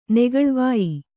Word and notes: ನೇಗಿಲ್ವಾಯ್: kannada

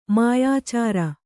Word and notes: ಮಾಯಾಚಾರ: mayācāra

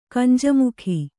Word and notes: ಕಂಜಮುಖಿ: Kannada

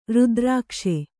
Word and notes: ರುದ್ರಾಕ್ಷ: rudrākṣa

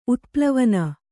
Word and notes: ಉತ್ಪ್ಲವನ: utplavana

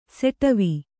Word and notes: ಷಾಡವ: Kannada

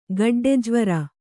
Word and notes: ಗಡ್ಡೆಜ್ವರ: gaḍḍe jvara

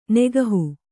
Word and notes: ನೆಗಹು: kannada

